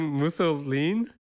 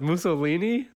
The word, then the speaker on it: mousseline: and he also kept mispronouncing mousseline as Mussolini, the dictator.